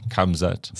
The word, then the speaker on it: Khamzat: Khamzat.